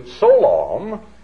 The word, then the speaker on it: Solawm: Because the root word is Selah, and it derives down to Solawm, S-O-L-A-W-M is the phonetic way it would be pronounced.